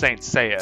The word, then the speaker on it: Saint Seiya: It's pronounced Saint Seiya. – Saint Seiya. Oh, look at me.